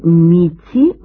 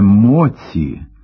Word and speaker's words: моці: Такі дії призводять до підриву економічної міці молодої держави. Це груба помилка. – Треба писати і вимовляти «міць» – «міцю», але «моці» – як «ніч» – «ніччю», але «ночі».